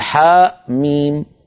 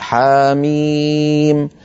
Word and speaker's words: حم: لا نقول حاء ميم بل نقول حاميم، لا نقول طاء هاء بل نقول طاها.